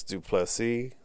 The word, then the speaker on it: du Plessis: But the next fight was Darren Till versus Dricus du Plessis. The S is silent at the end, motherfucker. Get it right.